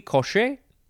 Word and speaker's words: Cauchy: It is spelled capital C-A-U-C-H-Y, second word, sequence.